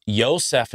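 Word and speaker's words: Yosef (יוסף): So Joseph, which would be pronounced Yosef in Hebrew, call the baby, he will save, because he will save his people from their sins.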